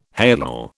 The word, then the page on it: halal: British English